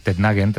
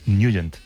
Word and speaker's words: Nugent: Hombre, siempre lo hemos llamado en el barrio Tednagen o Tednagen. – Claro, yo era de Tednagen. – Pero la pronunciación correcta es Nugent. – Te fuiste a Washington y descubriste que era Nugent.